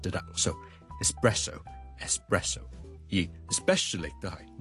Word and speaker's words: espresso: 今天有个朋友叫笑叔叔讲一讲这个话题就是呢有两个字 ES 字开头的分别是 ESPECIALLY especially 即是特别地呢尤其是呢 especially 第二个字呢都是 ES 开头的就是 ESPRESSO espresso espresso espresso 即是意式的特浓咖啡我们这两个字的 ES 呢我们都不要将它读成 X 不要 espresso 不要 especially but espresso E 或者 E 都得直接加个 S 中间唔好加咗个 K 音，